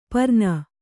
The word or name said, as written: ಪರ್ನ